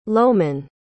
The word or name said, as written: lowman